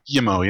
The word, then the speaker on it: Guillemot: You know, Yves Guillemot, or I think I'm pretty sure his last name is Guillemot, or is it Guillemot? – I think Guillemot, maybe. – Guillemot, yeah.